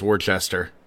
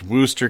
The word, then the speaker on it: Worcester: I see it as Worcester but I believe it's pronounced Worcester County, Massachusetts. 2007.